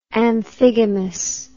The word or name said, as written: amphigamous